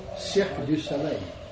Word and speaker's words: Cirque du Soleil: You know, we're not that's not to complain about, what is it, Cirque Soleil? Is that how you say it? I didn't say it right. The Circus of the Sun? – Oh, Cirque du Soleil. – Cirque du Soleil, yes.